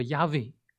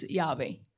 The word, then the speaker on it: Yahweh: 这个名字呢是中文翻译作耶和华，这个希伯来文呢读作 Yahweh。 – So God actually has a name. So in Chinese is 耶和华，in Yahweh， in Hebrew is Yahweh。 – 这个 Yahweh 这个词呢本来是一个动词。 – Yahweh was original verb. – 那么这个动词的意思呢，就是是，就是存在，就是那样一个，有点像英文的 is, are 这样的一个动词。 – So the meaning of Yahweh is yes, is or being.